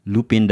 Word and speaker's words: Lupin: So it's spelled Lupin, but it's pronounced as Lupin.